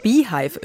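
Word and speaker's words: Beyhive: Wissen Sie, was der Beehive ist? Also mit Y in der Mitte. Das ist die Fan-Community von Beyoncé, spricht sich wie das englische Wort für Bienenstock.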